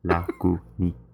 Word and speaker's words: Lagunitas: Yes, Lagunitas.